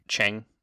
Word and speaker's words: Cheng: You guys get there, and Long, Cheng, I can't, his name is C-H-E-N-G, not C-H-A-N-G, and I cannot separate the A and the E vowel sounds.